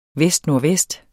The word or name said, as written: vestnordvest